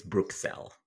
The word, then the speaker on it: Bruxelles: which was poorly pronounced by me, but I did try. – The only thing I would add to that is I think it's Bruxelles. And I know that only because that's how it's said in Arabic.